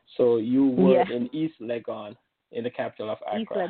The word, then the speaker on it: Legon: That's East Legon. Legon is spelled L-E-G-O-N.